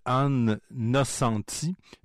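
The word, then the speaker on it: Ann Nocenti: Il s'agit de Anne Nocenti, exactement comme ça se prononce, et David Aja, A-J-A, au dessin.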